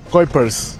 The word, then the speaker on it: Kuipers: ¿Cómo es? ¿Cómo se pronuncia? – Kuipers. – Pueden decir Kupi, ustedes. – Kupiers. – Kuipers. – Kuipers. – Hugo Kuipers. – Kuipers. Kuipers. Ok. – No, es Hugo Kuipers.